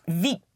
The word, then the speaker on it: vite: How would you pronounce V-I-T? – V. – Once more? – V. – V. How about you pronounce V-I-T-E? – Vite. – Vite. V-I-T-E.